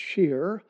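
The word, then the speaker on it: sheer (שיר): The word sing there is the Hebrew word shirah, and it's sometimes spelled uh, S H I Y R. And this verb, it's pronounced sheer.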